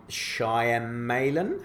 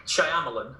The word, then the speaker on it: Shyamalan: That uh, uh, M Night Shyamalan, I think that's how you say his name. – Yeah, Shyamalan.